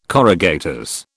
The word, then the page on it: corrugators: British English